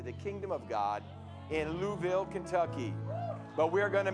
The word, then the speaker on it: Louisville: I was corrected when I went out there. I said, Louisville's nice. Louisville, Pastor, Louisville. So they're heading out this week,